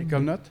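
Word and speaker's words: Ekuanot: C'est comme ça que tu le prononces? Équanote. Équanote, oui.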